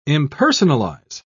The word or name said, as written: impersonalize